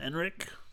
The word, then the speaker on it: Ehrenreich: But yeah, how do you say his name? – Alden Ehrenreich. Ehrenreich? – Ehrenreich? – Yeah, Ehrenreich. Yeah, Alden Ehrenreich.